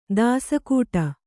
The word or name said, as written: ದಾಸಗೌಡ